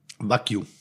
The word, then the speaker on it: Wagyu: Wagyu. – Wagyu? – Wagyu. – Ah, okay, da habe ich mich immer gefragt. Ich habe oder, Wa oder nenn es einfach japanisches Rind. – Okay, Wagyu.